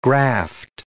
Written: graft

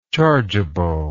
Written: chargeably

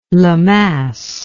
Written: Lemass

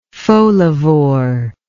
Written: folivore

